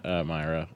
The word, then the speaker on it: Myra: I started the first mic I did by saying that I prepared for the mispronunciation of my name. I was like, oh, well, my name's Matt Myra. You said Mira, but I mean, you can call me anything you want except cuntface.